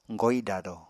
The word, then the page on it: goidado: Apatani